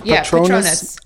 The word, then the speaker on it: Patronus: Patronus animal. Yeah. Actually has a Vine account, so you just have to you have to Google Vine, and then City Goat, and then that's my Patronus animal.